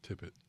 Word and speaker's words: Tippett: Tippett.